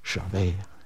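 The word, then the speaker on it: Jahve: Det är på samma vis du uttalar ordet Javé. Det börjar med en inandning och det slutar med en utandning.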